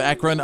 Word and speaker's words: Akron: There's a video from the Arlington neighborhood of Akron.